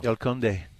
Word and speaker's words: El Conde: El Conde.